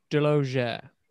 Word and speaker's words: Delaugere: Deloger. – How do you spell that? – D-E-L-O. – D-E-L-A-U-G-E-R-E.